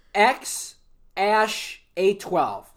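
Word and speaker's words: X Æ A-12: X-A-E-A-X-I-I. – It's pronounced X Ash... A12.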